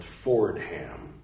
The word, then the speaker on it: Fordham: I'm sure it's Fordham University, but it's spelled Fordham. Like Ford followed by Ham.